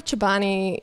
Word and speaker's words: Chobani: So Chobani, is that how you say it? Chobani? – Chobani. – Chobani. – Yeah.